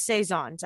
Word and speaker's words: Saison: So first on my list is Saisons. I know we've had an episode about Saisons before. – It's pronounced Saison.